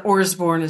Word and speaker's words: Orsborn: And it's on the book title, I'm sure it's on your website, but just notice that Orsborn is spelled with a couple of R's in it. – Yeah, Orsborn, O-R-S-B-O-R-N.